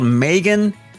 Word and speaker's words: M3GAN: It's called Megan. It is spelled M3GAN, and it's about this futuristic doll that is supposed to be kind of a companion to kids.